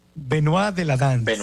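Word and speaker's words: Benois de la Danse: ¿Cómo se dice? – Se dice Benoit de la danza. – Benoit de la Danse.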